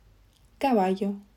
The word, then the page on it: caballo: Spain